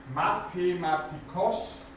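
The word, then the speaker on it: μαθηματικός: Die Betonung über den Omikron Mathematikos, wörtlich der Gelehrte, der etwas gelernt hat oder zu, zum Lernen gehörig, kann es auch heißen, Mathematikos.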